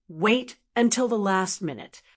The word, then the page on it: wait until the last minute: American English